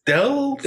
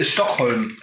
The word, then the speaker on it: STHLM: Teddy Bears Stealth? Äh, Stockholm. Ach, Stockholm.